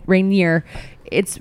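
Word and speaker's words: Raniere: I just remembered from the A&E documentary that his name is pronounced Rainieri. – No, it's not. – Yes, it is. Well, so if you guys were listening to this the whole time and just hating that we were pronouncing it Rainier, It's Rainieri.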